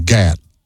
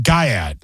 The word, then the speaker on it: Gyat: G-Y-A-T. – Oh, I was thinking Gat, like in, you know, are you strapped, you know? – But no, it's not.